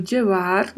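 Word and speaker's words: lleva: E atenção para a pronúncia. Jeva este duplo L tem aquelas variações que já comentamos em vários podcasts, né? De ter a sonoridade de LH ou de DJ, DJ e até mesmo de CH.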